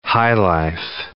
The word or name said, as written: highlife